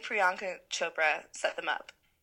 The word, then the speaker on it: Priyanka Chopra: Who I think set them up was Priyanka Chopra. Is that how you pronounce her name? – Priyanka Chopra.